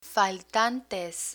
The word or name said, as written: faltantes